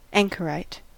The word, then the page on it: anchorite: American English